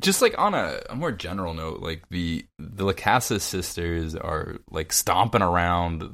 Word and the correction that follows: Lachesis: How do you say it? – It's the Where did I get Lacasis? I don't know. Abyssalis. – Because Lachesis is one of the sisters.